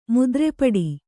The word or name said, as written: ಮುದ್ರೆಪಡಿ